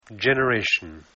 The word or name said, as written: generation